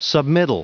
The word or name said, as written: submittal